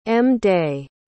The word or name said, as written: m-day